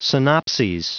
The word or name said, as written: synopses